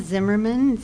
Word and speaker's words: Zimmerman: I'm Carla Zimmerman, Z-I-M-M-E-R-M-A-N.